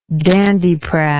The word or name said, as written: dandiprat